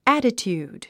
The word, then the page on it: attitude: ǽtit j ùːd; アティチュード